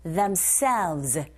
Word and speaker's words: themselves: People of Uros who need to supply themselves. 끝발음 하실 때 selves 하지 마시고 themselves. 에! 이 부분에 강세 주셔서 v 발음만 입술 잡아주시면 돼요. selves. selves. themselves 하실 수 있겠죠? 자, 그거 listening 1.